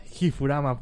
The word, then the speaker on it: Gifurama: G-I-F-U-R-A-M-A. Yo también me hago bolas porque pues no lleva u, Umuda, ¿no? Es Gifurama, pues se puede decir. Pues, pues se pues, pronuncia Gifurama, como Gifu. Así que pues muchas gracias, amiguitos.